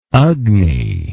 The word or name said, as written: agni